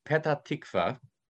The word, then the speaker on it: Petah Tikva: We want to go to Petah Tikva. But unfortunately, in Egypt, in the Egyptian Arabic, the letter P is rather difficult.